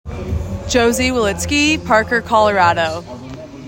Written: Josie Wolitzky

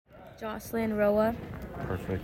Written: Jocelin Roa

